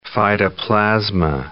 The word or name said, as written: phytoplasma